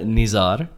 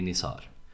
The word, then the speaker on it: Nizar: Mitt namn är Nizar. Det är liksom så man uttalar det på arabiska. I Finland får man ändra sätta tills, så det blir Nizar.